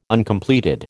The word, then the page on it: Uncompleted: American English